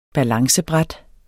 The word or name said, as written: balancebræt